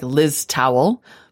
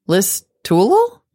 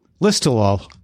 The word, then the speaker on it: Listowel: And he writes, I wanted to correct the pronunciation of the town, which sure looks like Liz Towel, but is pronounced Listowel? – Listowel.